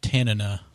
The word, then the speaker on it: Tanana: Together, it's pronounced Tanana. – Tanana? – According to another show on Discovery.